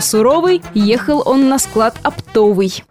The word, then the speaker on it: оптовый: Вся хитрость словечка в том, что оно является производным от существительного «опт». А у этого существительного во всех формах ударение падает на первый слог. Ну, например, «купить оптом». Поэтому интуитивно может возникнуть желание поставить ударение по аналогии и допустить ошибку. Все словари единодушны. Правильный вариант – оптовый.